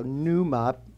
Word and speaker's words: pneuma: So pneuma, it's P-N-E-U-M-A. That's the way it's spelled, like pneumonia.